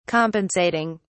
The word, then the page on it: compensating: British English